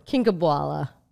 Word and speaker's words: Kinkhabwala: Kinkhabwala.